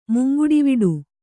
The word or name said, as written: ಮುಂಗುಡಿವಿಡು